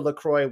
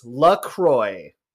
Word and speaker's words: LaCroix: how is LaCroix pronounced? And the answer is LaCroix. It rhymes with enjoy, which is a delightful little bit of marketing copy. Intentionally, it's not La Croix. It is LaCroix, American sparkling water.